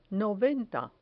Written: noventa

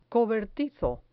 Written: cobertizo

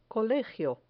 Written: colegio

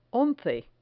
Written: once

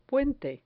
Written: puente

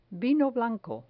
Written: vino blanco

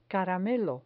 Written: caramelo

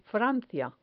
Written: francia

